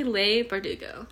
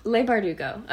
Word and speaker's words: Leigh Bardugo: How do you say her name? I would I would say Lay Bardugo. Lay Bardugo. Okay.